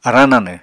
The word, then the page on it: arañ nanw: Apatani